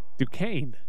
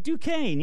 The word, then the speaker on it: Duquesne: Well, no offense. That's not how I'd spell Duquesne. – That's how it's spelled, though. – I know, but that's not how I'd spell it. I'd spell it phonetically. D-O-K-A-I-N.